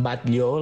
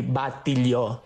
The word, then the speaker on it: Batlló: O sigui, en paraules com Batlló, la casa Batlló, nosaltres tenim aquest costum de dir batilló, o posar una I després, batilló.